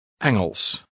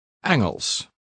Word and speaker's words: Engels: Engels. – How do you say English? – Engels.